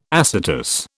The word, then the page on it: acetous: British English